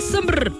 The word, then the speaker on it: Sombr: Y nos vamos con Sombr. Así se llama. Es Sombra, pero le quitaron la A. Sombr.